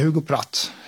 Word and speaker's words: Hugo Pratt: Och inte minst dess skapare Hugo Pratt, har jag alltid sagt. Men så kan jag ju rimligtvis inte uttala det själv. – Jag vet inte. Alltså, vi säger Hugo Pratt.